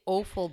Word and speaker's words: offal: It's O-F-F-A-L, but kind of. – That is how it's pronounced. Once again, was always like, it's offal. – Well, I've heard somebody say offal before, but I've always heard it referred to as offal.